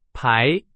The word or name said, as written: 排